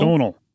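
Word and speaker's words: Domhnall: And just on the, how do you pronounce his first name? Donal? – Donal. – Donal? – Yes. It's one of those very, very, very Irish names.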